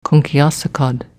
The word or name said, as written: Kunckkeasacod